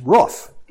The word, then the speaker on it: roof: "Because you mispronounced R-O-O-F." And I said, "How do you pronounce it?" She says, "Roof." And I said, "Roof." And she said, "Now you'll figure out who this is, because I'm always saying to my husband, he mispronounces it as well." I said, "Yeah, okay." Now, if I'd have been smart enough, I would have said, "Well, you know, I think it rhymes with hoof," but I didn't say that.